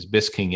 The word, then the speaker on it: Buesking: My last name is Busking. It's spelled B-U-E-S-K-I-N-G. And everybody calls me Busking or Busking, yep.